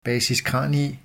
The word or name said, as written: basis cranii